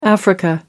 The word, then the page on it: africa: American English